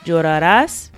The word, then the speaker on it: llorarás: So llorarás, it's very important to have that stress on the last syllable.